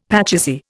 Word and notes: pachisi: Indian

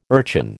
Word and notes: urchin: American English

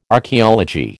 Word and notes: Archaeology: American English

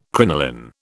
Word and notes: crinoline: British English

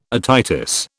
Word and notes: otitis: British English

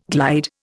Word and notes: glide: Indian

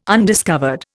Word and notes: undiscovered: Indian

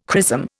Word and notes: chrism: Indian

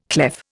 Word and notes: clef: Indian

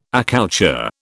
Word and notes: accoucheur: British English

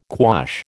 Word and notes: quash: American English

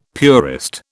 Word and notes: purist: British English

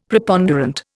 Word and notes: Preponderant: Indian

